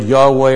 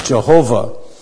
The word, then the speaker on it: Yahweh: Every time you come to the word Lord, where every letter is a capital, that's the word Yahweh, or sometimes mispronounced Jehovah.